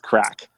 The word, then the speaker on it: Craic: So it's spelled like crake, but it's crack.